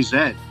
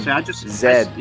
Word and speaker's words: Z: I like how you say it, because you say Zed. – Yes, at JON. – Zed. I say Z. That's not interesting.